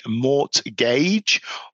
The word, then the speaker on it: mortgage: but these letters are actually silent. So the T in mortgage is silent, and the B in debt or doubt is also silent.